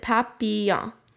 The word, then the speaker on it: Papillon: You pronounce it Papillon.